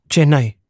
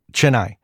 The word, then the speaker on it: Chennai: Chennai. – Chennai.